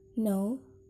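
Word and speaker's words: know: Jadi, semisal contohnya di sini adalah kata no. Jadi, cara bacanya itu dia di-delete yang di awal. Jadi, no bacanya, K-nya hilang.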